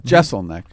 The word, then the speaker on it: Jeselnik: Jeselnik.